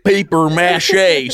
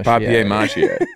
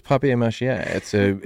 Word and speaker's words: papier mâché: Like It's papier French. Mâché. It's like a French word. – Yeah. – Papier mâché? – Paper mâché.